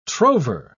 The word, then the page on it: trover: British English